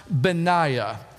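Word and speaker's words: Benaiah: But we're going to see in God's Word today someone with a name that looks kind of like Benihana, but it's not. It's actually pronounced Benaya. Benaya. Say that with me, church. Benaya. Now you got it.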